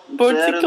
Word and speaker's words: Boursicot: Det handlar om en man som heter Bernard Boursicot eller något sånt. Han var fransk. – Och det uttalas så på franska? – Ja, va, mm, exakt så. – Boursicot.